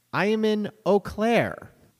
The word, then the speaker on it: Eau Claire: He says it's pronounced Eau Claire.